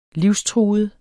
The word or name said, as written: livstruet